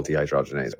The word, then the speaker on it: dehydrogenase: It's the byproduct, the alcohol dehydrogenase. I mean, I might have said that wrong. This is taking me back. – No, you said it right. Yeah, the liver converts it to alcohol dehydrogenase, which is the challenge it has to break down.